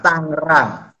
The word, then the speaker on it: Tangerang: Bukan oh. Tangerang, Tangerang. – Oh, bacanya Tangerang ya, oke. – Okay. G-nya cuma satu.